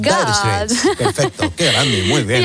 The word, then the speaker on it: Dire Straits: Y también vamos a seguir viajando en nuestra máquina del tiempo esta semana, que es a través de las canciones de Dire Straits.